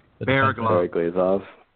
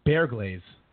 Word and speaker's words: Bereglazov: Bereglazov. – Neil Point. – Bear, and then glaze, like a donut. – Bear glaze.